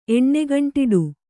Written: ಎಣ್ಣೆಗಂಟಿಡು

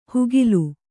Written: ಹುಗಿಲು

